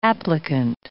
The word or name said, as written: applicant